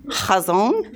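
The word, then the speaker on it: Chazon (חזון): The Hebrew word for vision here is chazon. Y'all, I looked up how to pronounce that. Chazon.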